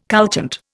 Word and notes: couchant: Indian

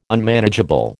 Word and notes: Unmanageable: American English

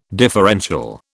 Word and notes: differential: British English

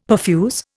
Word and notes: perfuse: Indian

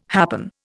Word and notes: happen: Indian

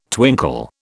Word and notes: twinkle: British English

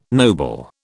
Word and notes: noble: British English

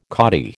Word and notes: kadi: American English

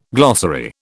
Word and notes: glossary: British English

